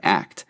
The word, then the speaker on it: ACT: It's actually just pronounced like the word ACT.